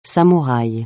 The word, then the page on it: samouraï: francais